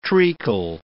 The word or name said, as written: treacle